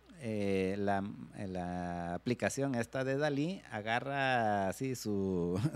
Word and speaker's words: DALL-E: aunque no se escribe igual, se escribe D-A-L-L-E, pero igual se pronuncia Dalí.